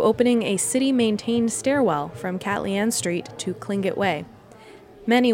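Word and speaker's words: K'alyaan: Many people just say Cat Leanne, and I have heard that one of the corrector pronunciations is Cat Leanne.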